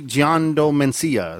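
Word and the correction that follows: Giandomenico: Did I say that right? Giando Mencia? Is it like Italian? – Gian Domenico. – What? – Gian Domenico.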